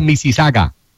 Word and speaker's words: Mississauga: Se pronuncia Mississauga.